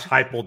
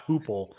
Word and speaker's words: Heupel: It's just like when you guys were calling Josh Heupel, Josh Hoople. Josh Hoople on this show.